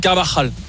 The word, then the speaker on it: Carvajal: Carvajal.